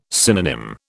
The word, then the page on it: synonym: British English